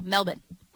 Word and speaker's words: Melbourne: Apparently, they don't pronounce the R. – Okay, so no R's. – No, Melbourne. Okay. Melbourne. You know how, but like you could still hear the.